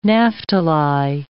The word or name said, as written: Naphtali